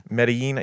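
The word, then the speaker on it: Medellín: Medellin. – It's Medellin. – Medellin. Yeah, I'm making Medellin. It very white. – Yeah, because it's got two L's at the end, so it's a Y sound.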